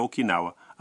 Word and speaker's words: Okinawa: Diga que você já foi a Okinawa.